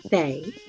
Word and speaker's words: Fae: Ow, Fee. Fee. That's how you say it, Fee, right?" – Fae. – Ah. Like a fairy.